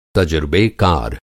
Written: तजुर्बेकार

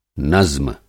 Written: नज़्म

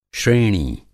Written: श्रेणी

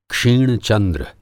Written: क्षिण-चंद्र

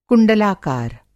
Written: कुंडलाकार

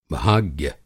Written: भाग्य

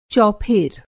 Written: चौफेर

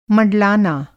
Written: मँडलाना

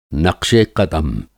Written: नक़्शे क़दम

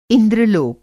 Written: इंद्रलोक

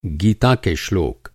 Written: श्लोक

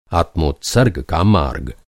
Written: आत्मोत्सर्ग

